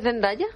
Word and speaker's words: Zendaya: ¿Cómo se dice Zendaya? – Zendaya.